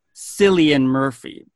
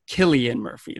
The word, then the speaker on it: Cillian: And logically, you would think his name is pronounced Cillian Murphy, but it is in fact named or pronounced Killian Murphy. That is the proper Irish pronunciation of his name.